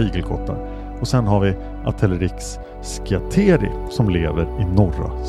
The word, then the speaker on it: Atelerix: Ett släkte är Atelerexis, förekommer huvudsakligen i Afrika och är nära släkt med arterna i Erinaceus, bland Atelerix, så uttalas det, förekommer afrikansk pygmé och eh, Atelerix frontalis som lever i södra Afrika från Angola och Zimbabwe till Sydafrika.